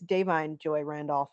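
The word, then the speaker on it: Da'Vine Joy Randolph: And Divine Joy. Well, it's actually, apparently it's Davine Joy Randolph. I've been pronouncing it wrong completely for ages now.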